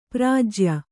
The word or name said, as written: ಪ್ರಾಜ್ಯ